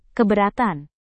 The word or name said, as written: keberatan